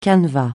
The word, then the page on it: canevas: francais